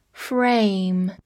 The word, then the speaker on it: frame: F R A M E，frame，a 发字母本身的音 a，末尾的 e 不发音。 frame，它是一个名词，表示框架或者结构。